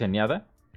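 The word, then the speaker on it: Enéada: La Eneada... Sí, es Eneada. Lo dije bien.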